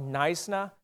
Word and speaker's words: Knysna: The town that we're going to is, as you see it there in the bottom with the K, it's a silent K, it's called Knysna, South Africa.